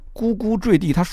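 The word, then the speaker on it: 呱呱坠地: 或者说是瓜，它是个多音字，你可以读作瓜，你也可以读作咕。而由于咕咕坠地，它属于成语，因此在编写词典的时候，专家呢对于这个咕的发音就选择了文读音叫咕，而舍弃了白读音就是瓜。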